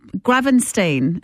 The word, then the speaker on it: Gravenstein: So we'll Gravenstein, is that how you pronounce it? – Yes.